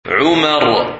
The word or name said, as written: Umar